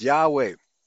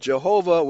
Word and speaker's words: Yahweh: Note what the New Catholic Encyclopedia says under Yahweh, quote, "Judging from Greek transcriptions of the sacred name, YHWH ought to be pronounced Yahweh. The pronunciation Jehovah was unknown in ancient Jewish circles," right?